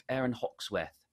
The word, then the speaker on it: Hawksworth: The Open Championship, Aaron Hawksworth. – Ah, my name is English, so you pronounced it correctly.